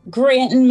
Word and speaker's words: Granton: We have Carrie Williams of Granton? – Granton Manor Haunt. G-R-A-N-T-O-N.